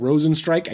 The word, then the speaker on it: Rozenstruik: Rosenstrike. I guess we're saying Rosenstrike now. That's how you say it.